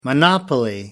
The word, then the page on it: monopoly: American English